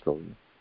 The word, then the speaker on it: Prabhupada: Yes, Prabhupada.